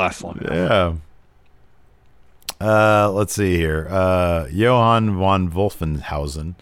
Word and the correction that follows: Johann von Wolfhausen: says, since I just butchered that name, Johann von Wolfhausen, since everyone is getting a manager, who should be X Laxes?